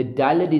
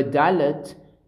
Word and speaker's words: Dalet: And this, by the way, In Yemenite pronunciation, the Dalet is, I think, more like a V.